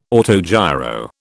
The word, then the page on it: autogiro: British English